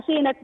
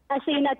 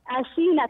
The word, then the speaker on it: أسينة: لا، مش أثينات. – ها، طيب ما هو؟ – أسينة ينطق بالسين، بارك الله فيه. – أسينة، آسي، أسينة، نعم.